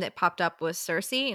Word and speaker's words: Sursea: This was S-U-R-S-E-A. Cersei. Easy peasy.